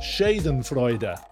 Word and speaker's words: Schadenfreude: Schadenfreude is the correct way to say the word, apparently.